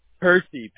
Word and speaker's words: Percy: Percy, P-E-R-C-Y.